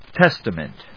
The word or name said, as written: Testament